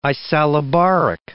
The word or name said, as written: isallobaric